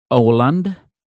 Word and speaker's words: Åland: Yeah, she's based on, I have to say this in Swedish, because there is no other way, and it's funny, it's pronounced Åland, it's the Åland Island, and okay.